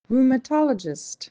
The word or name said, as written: rheumatologist